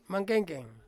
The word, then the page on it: mangkengkeng: Ansus